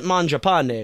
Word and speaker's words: Mangiapane: I pronounce it Mangiapane because that's like an Italian name and it means eat bread.